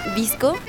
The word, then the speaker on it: VSCO: Pues fíjate que hay una app a la que podría decirse que soy, le, le soy fiel, que se llama Visco, se escribe V-S-C-O que bueno,